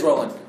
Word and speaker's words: Rowling: All I'm saying is freaking Rowling. J.K. Rowling. – Rowling? – No. I think it's Rowling. – It's a Rowling. It's a Rowling. – Rowling? – It's not Rowling. It is Rowling.